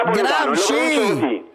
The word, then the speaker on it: Gramsci: Ma Gramsci, no, Grami. Ma non Gramsci.